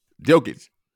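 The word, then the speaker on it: Jokić: How you pronounce last name again? Say it again. – Jokic or Jokic. – Jokic. I'll pronounce it that way.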